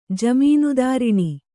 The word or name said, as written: ಜಮೀನ್ದಾರಿಣಿ